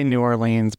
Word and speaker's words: New Orleans: So it says, when four lifelong friends travel to New Orleans, do you say New Orleans or New Orleans? – I say it wrong. I say New Orleans, but nobody says, I don't feel like that's the right way to say it. – It's New Orleans.